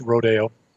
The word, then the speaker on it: Rodeo: Some people call it Rodeo, but it's actually pronounced Rodeo.